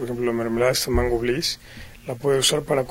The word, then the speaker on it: Bliss: Bliss.